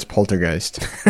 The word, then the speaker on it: poltergeist: Poster heist. I think there's a. It's like a soft G. It's like a high. – I got it. – I don't know. How do you pronounce poltergeist? – Poltergeist.